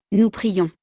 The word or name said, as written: nous prions